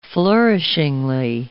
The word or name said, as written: flourishingly